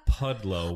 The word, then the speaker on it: Pudlow: Pudlow, with no W.